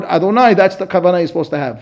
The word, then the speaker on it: Adonai: We pronounce it Aleph, Dalet, Nun, Yud, Adonai.